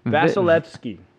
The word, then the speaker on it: Vasilevskiy: Only thing you're missing is you got to pronounce the V a little bit more. – Andre Vasilevskiy. I don't even know. Okay. Vasilevskiy? – Vasilevskiy.